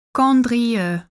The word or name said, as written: Condrieu